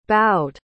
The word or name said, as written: boughed